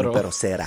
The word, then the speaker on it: Junipero Serra: Junipero Serra.